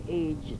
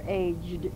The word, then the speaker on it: aged: Most prayers are for the young, O Lord, but this is for the aged. Now, that can be pronounced aged or aged, either way you want to, but I have always pronounced it aged. The dictionary tells you either way is right.